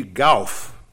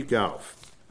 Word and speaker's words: Gough: Gough. I looked up the pronunciation, G-O-U-G-H.